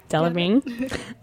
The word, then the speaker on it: Delevingne: We also, of course, have the pizza onesie worn by both Katy Perry and Cara Delevingne. Did I say that right? – Yeah. – Delevingne. Yeah.